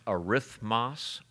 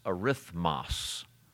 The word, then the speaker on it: arithmos: Do you know that the Greek word number, you pronounce arithmos, arithmos.